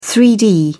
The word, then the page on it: 3-d: British English